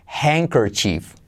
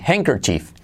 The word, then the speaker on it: handkerchief: Hand, como mano, pero sin de hand. ker como sister, chief, con una I. Eso sí que es una I. Handkerchief. Handkerchief. ¿Conmigo? Handkerchief. No pronunciamos la D.